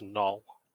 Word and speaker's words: gnoll: How do you pronounce gnoll or gnoll? What is your preferred way? – It's null.